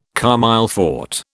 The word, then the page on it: comme il faut: British English